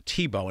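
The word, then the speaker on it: Thibault: Again, if you're looking for the author's name, it's a little different than it sounds. It's T-H-I-B as in boy, A-U-L-T, but pronounced Thibeault.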